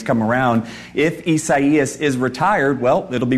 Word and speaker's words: Isaias: Isaias, which I believe is another way of saying Isaiah. So again, Isaias.